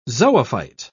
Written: zoophyte

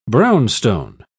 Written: brownstone

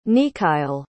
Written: neakail

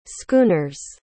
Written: schooners

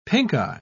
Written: pinkeye

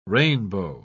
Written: rainbow

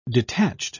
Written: detached

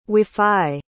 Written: wafai

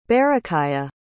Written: berakhiah

